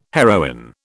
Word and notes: heroin: British English

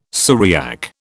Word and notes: Syriac: British English

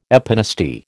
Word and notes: epinasty: American English

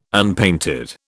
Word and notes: unpainted: British English

